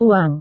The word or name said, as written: uang